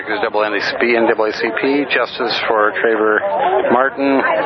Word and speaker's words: Trayvon: That's Trayvon Martin, to my embarrassment. I kept mispronouncing the name.